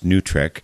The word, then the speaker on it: NWTRCC: Fortunately, there are groups like the National War Tax Resistance Coordinating Committee, NWTRCC, often pronounced NUTRIC,